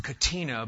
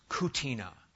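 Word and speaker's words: catena: When you put a bunch of passages together and have a series of excerpts, it's spelled C-A-T-E-N-A, C-A-T-E-N-A. And you might think that's pronounced Katina, but it's Kutina.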